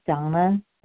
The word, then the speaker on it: Dana: This is Donna B. My first name is spelled D-A-N-A.